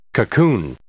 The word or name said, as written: cocoon